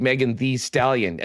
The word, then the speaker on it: Megan Thee Stallion: but listen, I'm a I big Megan Thee Stallion, and it's two E's in the. Uh, very correct pronunciation of that.